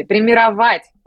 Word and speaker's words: примировать: Примировать правильное ударение. Я настаиваю, чтобы все запомнили, примировать.